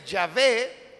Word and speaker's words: Yahvé: Y el otro, que es el relato yavista, se llama yavista porque para referirse a Dios utiliza el nombre de Yahvé, que se cree es la pronunciación con bastante seguridad del tetragrama, que son las cuatro letras, consonantes todas ellas, que era la manera como se escribía el nombre de Dios.